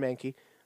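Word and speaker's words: Mahnke: That's how I remember his last name. It's pronounced exactly like the Pokemon. Aaron Mankey.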